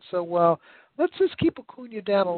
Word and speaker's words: Acuña: Acuña is ready to go.